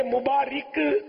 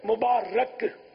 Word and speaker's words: مبارک: یہ مبارک غلط العوام ہے, غلط تلفظ ہے. مبارک یہ درست تلفظ ہے.